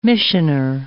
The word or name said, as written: missioner